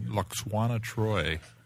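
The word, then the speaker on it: Lwaxana Troi: She played Luxuana Troi.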